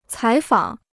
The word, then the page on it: 采访: cǎi fǎng